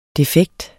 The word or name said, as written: defekt